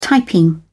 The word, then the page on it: typing: British English